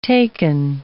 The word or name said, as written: taken